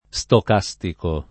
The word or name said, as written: stocastico